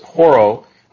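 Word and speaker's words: horao: So it uses the Greek word seen here, 1492, but it uses the Greek word 3708, and it is horo, H-O-R-A-H-O, or pronounced that way, it's H-O-R-A-O, and it means to perceive.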